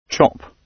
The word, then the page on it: chop: British English